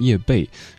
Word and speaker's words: 叶蓓: 纯真年代，九八年玉东写的叶培，对我们习惯念叶培，但其实应该念叶蓓。